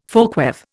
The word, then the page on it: folkweave: Indian